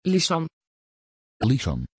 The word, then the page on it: Lysan: Dutch